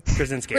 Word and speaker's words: Krasinski: Krasinski.